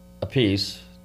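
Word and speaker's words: Appice: So when you look at Beck, Bogart, and a piece, did I say that right? Piece? Okay. – Yes.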